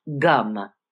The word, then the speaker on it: Gam: Now you notice it's spelled G-A-M, and it's spelled G-A-N-A-P, but it's gum.